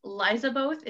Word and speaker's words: Lizaboth: So on Twitter, it's Liza Both is how it's pronounced, because most people don't know. It's a childhood nickname, but it's L-I-Z-A-B-O-T-H.